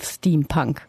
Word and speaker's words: Steampunk: Jetzt muss ich erstmal fragen, woher kommt dieser Begriff Steampunk? Habe ich den überhaupt richtig ausgesprochen? – Das ist schon genau korrekt so.